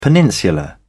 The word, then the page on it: peninsula: British English